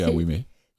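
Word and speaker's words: Awumey: Donc, dans la série de Vive Voix, là, c'est l'écrivain, c'est une lecture en ligne avec Edem Awimé. J'espère que je prononce bien son nom. – Oui, Awimé.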